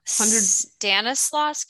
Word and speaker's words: Stanislaus: Stanislaus County.